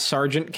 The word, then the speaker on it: Sargent: You can find me at Sergeant Cass. S-A-R-G-E-N-T. That's how my last name is spelled. It's not a misspelling of the military rank.